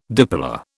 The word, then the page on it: dipolar: British English